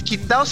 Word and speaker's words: Qingdao: Por alguna razón el, el Qingdao se pronuncia Qingdao, no tengo idea por qué.